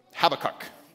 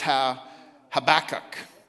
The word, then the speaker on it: Habakkuk: The emphasis is on the ba. So it's Habakkuk, which is going to be hard for your brain to get around.